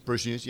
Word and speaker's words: Brews News: But let's talk about what Brew News Australia. Did I say it right? Brew News Australia. – Brews News. Yep.